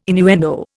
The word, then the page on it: innuendo: Indian